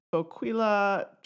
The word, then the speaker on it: Boquila: It's a vine called um, Boquila trifoliata. I think in Spanish it would be pronounced boquila, boquila. So I'm going to say it that way because that's probably the way Ernesto, Ernesto says it.